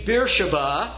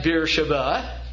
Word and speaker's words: Beersheba: This is, as you can see, Beersheba, though it's pronounced a number of different ways, and Hebrews ordinarily would put a V where that last B is.